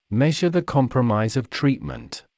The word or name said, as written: measure the compromise of treatment